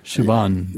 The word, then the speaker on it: Siobhan: Siobhan. – Siobhan. – Yep. – I know how to say it. I don't know how to spell it. I know how to spell it, too. It's Gaelic spelling.